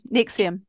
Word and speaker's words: NXIVM: NXIVM.